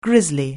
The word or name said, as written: grisly